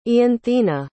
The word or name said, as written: ianthina